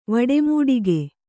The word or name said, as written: ವಡೆಮೂಡಿಗೆ